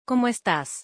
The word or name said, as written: ¿Cómo estás